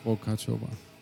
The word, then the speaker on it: Vokáčová: Welcome, Magdalena Vokáčová.